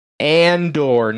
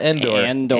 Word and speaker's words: Andor: Andor. Andor, not Endor.